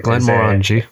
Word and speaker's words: Glenmorangie: So Glenmorangie in our big. – Glenmorangie. – Just think orangey, just orangey, orangey, orangey, Glenmorangie.